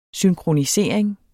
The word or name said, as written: synkronisering